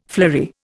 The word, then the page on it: flory: Indian